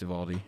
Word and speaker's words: Uvalde: He's from Uvalde, if that's how you pronounce that. I think it's Uvalde.